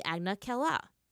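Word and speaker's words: Agna Qel'a: The canonical name of Northern Water Tribe City is actually Agna Kella, as revealed in the novel. By the way, guys, that's spelled A-G-N-A, like Agna. And then Kella is Q-E-L-apostrophe-A.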